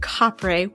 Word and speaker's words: kapre: Because there wasn't an F sound in the Filipino phonology, the F came to be pronounced as a P, and thus the word kapre was born.